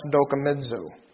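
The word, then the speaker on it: dokimazo: It's D-O-K-I-M-A-Z-O. And it's pronounced dokimizo.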